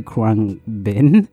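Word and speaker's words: Khruangbin: And to people who don't listen to Krangbin, what are they, that's how you pronounce it.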